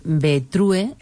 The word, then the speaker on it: Betrue: Betrue, se escribe así, Betrue mujer. Seguramente que van a escuchar decir Betru mujer.